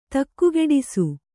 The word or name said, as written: ತಕ್ಕುಗೆಡಿಸು